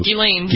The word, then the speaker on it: Ghislaine: With the update on Ghislaine. I never pronounce her name right. I don't think I do. – Ghislaine. Yeah. – It is right.